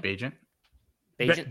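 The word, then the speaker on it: Bagent: So could I have drafted Tyson Bagnet, whatever you pronounce his name? – Bajent.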